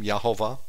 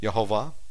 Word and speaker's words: Yehovah: Verse 31, and in the wilderness, where thou hast seen how that Yahweh, thy God, who is our God, he has a name, Yahovah, Y-E-H-O-V-A-H, that's his name, Yahovah, short is Yah. I know you spell it Y-A-H, shortened, but that's not how they do it in Hebrew. So in the long form, it's Y-E-H, not Y-A-H.